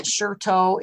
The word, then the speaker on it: Shitou: And if you just Google Song of the Grass Roof Hermitage, the name of the teacher is Sherto. It's spelled S-H-I-T-O-U.